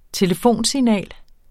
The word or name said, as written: telefonsignal